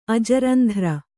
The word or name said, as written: ಅಜರಂಧ್ರ